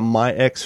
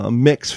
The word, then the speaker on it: MYX: Long story short, she found one called, I keep calling it My X Fitness because that's how it's spelled, MYX Fitness, but it's actually called Mix Fitness.